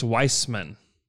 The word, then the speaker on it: Wiseman: And you put him out there with Wiggins and Oubre and Wiseman and are we calling him Wiseman or Weissman? Have we decided that, by the way? – Wiseman. – Because he's he came out and said it's pronounced Weissman.